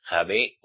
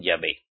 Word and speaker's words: Yahvé: Y si se dan de cuenta, cristianos, en la Biblia católica de Roma es Javé o Yahvé, según cómo pronuncies la J. Más bien, dicen Yahvé, según el idioma, porque en portugués la J es Ye. Como mujer, por ejemplo.